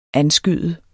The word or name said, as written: anskyde